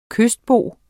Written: kystbo

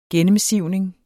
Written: gennemsivning

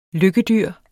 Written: lykkedyr

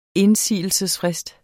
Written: indsigelsesfrist

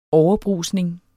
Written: overbrusning

